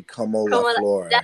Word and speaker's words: Como La Flor: But my favorite would have to be Como La Flor.